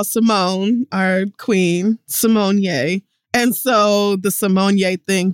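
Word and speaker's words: Symoné: It's Raven Simonier. – Oh, it really is that? – Yeah, and not Simone.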